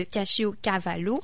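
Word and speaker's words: caciocavallo: Une première variété avec des lardons, le cacio cavallo. Je ne sais pas si je le prononce bien, mais c'est un fromage... Ah, bah oui, merci, monsieur l'Italien.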